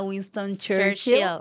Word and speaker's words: Winston Churchill: Están ubicados en la avenida Winston Churchill, en la 174, por fin lo dije bien, casi 4 años después.